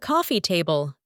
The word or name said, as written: coffee table